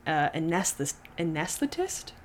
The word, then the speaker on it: anesthetist: And she helped support John through nursing school and while earning a license as an anesthetist? It's not an anesthesiologist, but it's like a nurse anesthetist. I don't know. – My uncle does that. Oh. And that is how you say it.